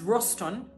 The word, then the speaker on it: ratson: The word is roston. It's spelled R-A-T-S-O-N.